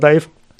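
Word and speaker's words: DIIV: Um aber Überschneidung mit dem belgischen Industrial-Projekt Dive, also genauso geschrieben, zu vermeiden, änderten sie sich ihre Schreibweise kurzerhand in Dive, Deiv. Ausgesprochen bleibt es weiter Dive.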